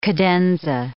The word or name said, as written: cadenza